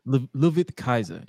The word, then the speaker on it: Ludwig Kaiser: Ludwig Kaiser, yeah.